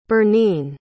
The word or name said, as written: berneen